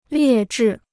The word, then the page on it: 劣质: liè zhì